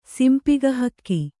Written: ಸಿಂಪಿಗಹಕ್ಕಿ

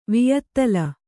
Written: ವಿಯತ್ತಲ

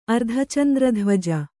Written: ಅರ್ಧಚಂದ್ರಧ್ವಜ

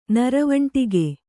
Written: ನರವಂಟಿಗೆ

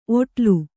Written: ಒಡಲು

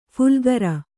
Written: ಫುಲ್ಗರ

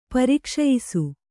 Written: ಪರಿಕ್ಷಯಿಸು

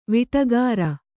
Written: ವಾಟಿಕಾರ

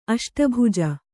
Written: ಅಷ್ಟಭುಜ

